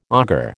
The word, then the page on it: augur: American English